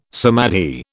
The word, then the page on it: samadhi: British English